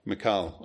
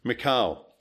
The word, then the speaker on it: Michal: It doesn't stop with Saul's son, but God also delivers to David Saul's daughter, whose name gets pronounced a multitude of ways. How did you call it? – Michal. – I'll go with that. Michal, who is also described as to love David.